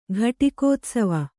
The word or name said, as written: ಘಟಿಕೋತ್ಸವ